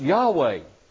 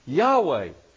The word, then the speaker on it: Yahweh: Pronounced Yahweh. Yahweh.